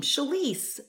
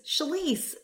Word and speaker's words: Shalise: And it rhymes with my name, Shalise, Shalise, the Union Police.